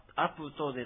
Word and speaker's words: アプト: このアプトというこの雨という言葉ですが、あ、カタカナで書くとアプトとなりますが、プーはですね、ちっちゃく書くアイヌ語の特殊な発音です。アプトでなく、アプトですね。アットでもなくアプトとなるのでちょっと難しいと思います。